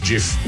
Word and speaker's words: GIF: And yes, it's pronounced GIF. I will die on that hill. And so will the guy who invented them, who insists that it is pronounced GIF.